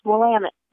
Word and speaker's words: Willamette: One of the ways that the tour companies have you remember that is they say it's Willamette, damn it.